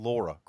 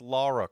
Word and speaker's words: Lara: How do you say, we were saying Laura Croft, but apparently, and I listened to the audio in the game again, it's Lara Croft, right? – Yes, it is Lara.